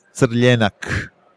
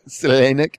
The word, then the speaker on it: Crljenak: Croatian name for Zinfandel is Crljenak. – Great. Yep. Crljenak.